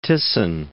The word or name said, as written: Thyssen